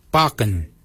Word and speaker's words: baginy: For beard, we say barken. Barken is spelled B-A-G-I-N-Y. Barken. Barken is your beard. The upper mouth, we say warrigal. Warrigal. Warrigal is spelled W-O-R-R-A-G-O-L.